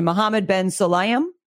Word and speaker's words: Mohammed Ben Sulayem: And it was actually supported by FIA's president, Statman, you're going to have to help me with this pronunciation, Mohammed Ben Sulayem. – That's close enough.